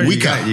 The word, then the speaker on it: Juica: Did I say it right? – Yes, sir. Juica. You got it right.